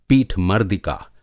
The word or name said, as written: पीठमर्दिका